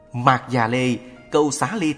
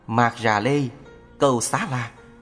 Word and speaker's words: Mạc Già Lê Câu Xá Ly: Mạc Già Lê Câu Xá Ly Tử, phiên âm từ Phạn ngữ là Maskari Gosha Liputra, cũng đọc là Mạc Già Lê Câu Xá La, Mạc Già Lê Câu Xá Lợi, vân vân.